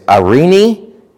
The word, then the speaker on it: Eirene: It's Eirene. It's E-I-R-E-N-E, Eirene.